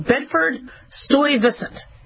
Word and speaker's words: Bedford-Stuyvesant: I apologize for mispronouncing it. I should have a very good pronunciation of most Brooklyn neighborhoods, but I don't. Bedford-Stuyvesant.